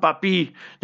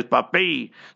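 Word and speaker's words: Pappé: So, not Papi, they pronounce it as Papay.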